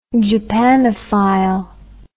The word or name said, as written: japanophile